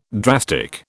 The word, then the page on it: drastic: British English